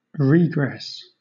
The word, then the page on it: regress: British English